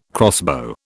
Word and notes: crossbow: British English

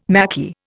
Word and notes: Maquis: Indian

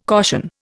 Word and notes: caution: Indian

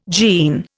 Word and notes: gene: Indian

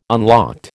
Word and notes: unlocked: American English

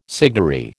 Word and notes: signary: American English

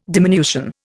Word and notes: diminution: Indian

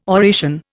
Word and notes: oration: Indian

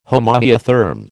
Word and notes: homoiotherm: American English

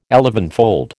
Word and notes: elevenfold: American English

